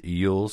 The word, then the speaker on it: jul: One says Yule, spelled J-U-L, but pronounced the same, is the word for Christmas in Norwegian, Swedish, and Danish, even today.